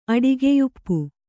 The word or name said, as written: ಅಡಿಗೆಯುಪ್ಪು